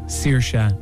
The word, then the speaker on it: Saoirse: it is pronounced Saoirse and nothing else.